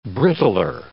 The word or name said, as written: brittler